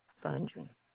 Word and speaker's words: Fondren: Rosetta Fundry. – Thank you. And could you please spell that last name? – Yes, F-O-N-D-R-E-N.